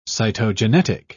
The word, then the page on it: cytogenetic: British English